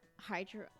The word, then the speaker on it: hyaluronic: It's 1 milliliters of hyaluronic. How do you say it? Hyaluronic there you go.